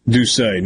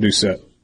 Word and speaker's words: Doucet: But Debbie in Ocean Springs tells me that it's pronounced Doucet, not Doucet. Jacques Doucet? – Makes sense. Right? That's how the French would say it.